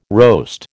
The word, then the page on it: roast: American English